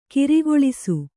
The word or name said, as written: ಕಾರಗಲಿಸು